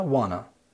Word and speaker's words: wanna: want to لما بنتكلم سريع بيبقى نطقها كأنها wanna.